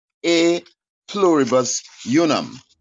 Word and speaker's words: E Pluribus Unum: E Pluribus Unum. How do you say that one? – That one, Dallas, is A. The E is in Latin. It's pronounced E Pluribus Unum.